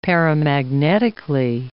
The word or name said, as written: paramagnetically